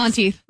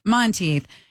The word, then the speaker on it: Monteith: Monteith. – Monteith, in the first season finale of Glee, along with several other tunes from this band in that final episode.